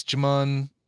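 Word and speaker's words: Djimon: It's, I don't think you pronounce the D. I think it's Jimon Hansu.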